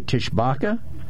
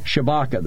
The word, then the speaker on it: Tshibaka: You know, I don't know how to pronounce this woman's name. It's spelled T-S-H-I-B-A-K-A, Tishbaka? – No, Shibaka. – Shibaka, then. S-H-I-B-A-K-A.